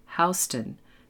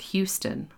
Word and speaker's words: Houston: And remember to pronounce the name of the street Houston, not Houston, like the city in Texas.